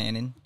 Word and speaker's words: Anin: We have John Annen. Did I say that right? – John Annen.